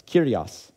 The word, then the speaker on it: kyrios: And the Hebrew word that Jesus used for both Lords was kyrios. I am pronouncing that properly. It's kyrios. The words, so those things are of equal meaning.